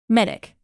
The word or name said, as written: medic